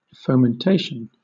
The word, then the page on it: fomentation: British English